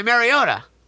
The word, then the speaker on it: Mariota: They were up 21-3 at halftime, blew the lead, fell 22-21 to the Titans on a self-touchdown pass by Mariota. You heard me say that correctly.